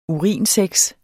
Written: urinsex

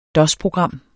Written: dosprogram

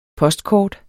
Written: postkort

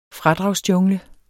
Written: fradragsjungle

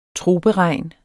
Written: troperegn

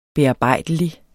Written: bearbejdelig

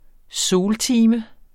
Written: soltime

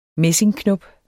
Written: messingknop

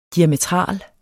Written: diametral

